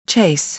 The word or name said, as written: chase